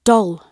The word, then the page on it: doll: British English